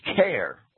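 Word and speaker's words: Caer: Well, let me say that that word care or car, C-A-E-R, I'll pronounce that care, right?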